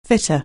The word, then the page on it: fit: anglais